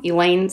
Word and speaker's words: Elaine: Elaine spelled the same way as in Seinfeld.